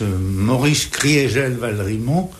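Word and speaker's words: Maurice Kriegel-Valrimont: On prononce Maurice Criégel Valrimont pour marquer que en 18, l'Alsace a cessé d'être germanisée.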